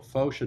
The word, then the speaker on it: Foch: This is how you pronounce Foch.